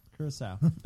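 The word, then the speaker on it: Curaçao: Curacao. – Curacao. – Curacao. Curacao. Curacao. That sounds like corazón. – Corazón. – Curacao. – Curacao. – That's the Portuguese pronunciation. – Oh, well, that's wrong.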